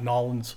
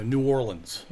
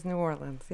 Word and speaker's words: New Orleans: Now, is that the proper way to say New Orleans? – It is. – It's not Nolens? – It is not Nolens. – All right, so New Orleans. – It is New Orleans, yep.